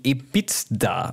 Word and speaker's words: EBITDA: Also ich hoffe, es ist richtig ausgesprochen, ich kann es noch runterlesen, EBITDA, also E-B-I-T-D-A, EBITDA hätte ich jetzt einfach mal gesagt.